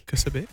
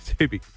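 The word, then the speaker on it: Ksubi: Kasubi. – Subi. – With a silent K.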